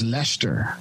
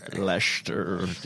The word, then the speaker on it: Leicester: I think it's just Leicester. – Yeah, it's just Leicester. – Leicester.